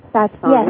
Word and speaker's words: satsangs: Yeah, if I'm pronouncing it, satsangs.